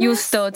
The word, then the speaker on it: Juusto: Juusto tattooed on my arm. – How do you spell it? Let me see. Oh. – J-U-U-S-T-O.